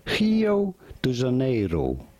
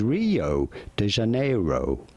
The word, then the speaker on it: Rio de Janeiro: In Brazilian, it is usually pronounced as Rio de Janeiro. Rio de Janeiro. But in English, it is usually said as Rio de Janeiro.